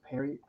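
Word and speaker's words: Pierrot: I think it's pronounced Perrot.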